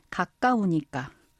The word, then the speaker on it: 가까우니까: Krachaunika c'est, donc prononciation standard, kakaunika.